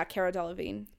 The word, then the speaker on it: Cara Delevingne: Yeah, Cara Delevingne. – Cara Delevingne.